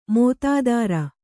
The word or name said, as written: ಮೋತಾದಾರ